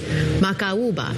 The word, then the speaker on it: Macaúba: M-A-C-A-U-B-A. It's hard when those vowels are dancing with one another, the A and the U.